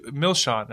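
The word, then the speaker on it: Milsean: is you tend to hit the first syllable hard. So it's Milshan. So that's why it's not Milshan, it's Milshan.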